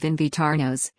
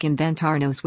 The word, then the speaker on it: invitarnos: Buttigieg stumbled over the Spanish pronunciation of invitarnos, inviting, which sounded like inventarnos, which means inventing.